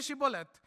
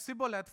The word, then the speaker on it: Shibboleth: If he said no, then they would call him, say to him, "Say Shibboleth." But he said Sibboleth, for he could not pronounce it correctly.